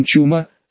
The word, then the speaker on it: Чума: пока наконец жрец с ничуть не говорящим именем Чума, с ударением на первый слог, не решил задать вопрос в лоб.